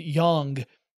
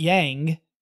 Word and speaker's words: Yang: and that's where I learned that the right pronunciation was that ah sound, not an a sound. So like she had a colleague named Yang, not Yang. It's spelled Y-A-N-G, but Yang.